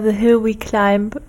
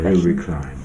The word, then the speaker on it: The Hill We Climb: Nee, also ich würde es eher The Hill We Climb aussprechen. The Hill We Climb.